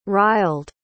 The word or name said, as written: riled